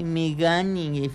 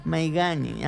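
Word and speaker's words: Maignan: el portero Mickey Maigani, así se pronuncia, ¿eh? Maigani, ándale. Y de la defensa, Jules Conde.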